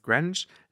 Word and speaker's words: Greenwich: Ähm, Greenwich ist ja auch, wird ja Greenwich geschrieben, aber es ist Greenwich.